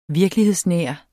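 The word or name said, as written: VIRKELIGHEDSNÆR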